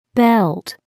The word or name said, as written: belt